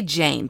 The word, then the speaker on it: Jeyne: How do you say it? Jane or Jean? Let's say Jane Poole. J-E-Y-N-E. Jane.